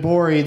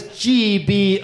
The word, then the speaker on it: Gborie: It's funny you're telling people that have only heard me call you David Gborie that the G is silent. Like they're like, what G? Where? What's going on? So his name is Gborie.